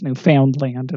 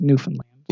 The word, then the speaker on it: Newfoundland: But which is not even pronounced Newfoundland, it's Newfoundland.